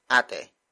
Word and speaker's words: ate: In this case, that is ate. Ate. Ate. Ate is spelled as A-T-E.